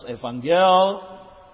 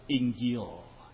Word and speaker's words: Injil: From Evangelion, the Ethiopian pronounces Evangel, and the Egyptian, the Copt, pronounces as Injil.